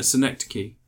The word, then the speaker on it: Synecdoche: Yeah, Synecdoche. No, Synecdoche. That's how it's pronounced.